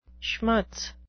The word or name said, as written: schmutz